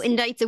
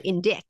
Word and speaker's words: indict: So indict's a weird word, right? Because it has a CT at the end, but you don't pronounce it indict.